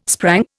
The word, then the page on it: sprang: Indian